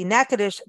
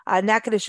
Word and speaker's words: Natchitoches: And in closing, last but not least, we want to thank the Natchitoches Convention and Visitor Bureau for sponsoring today's show. Natchitoches is the oldest city in the state of Louisiana, and you've got to go there.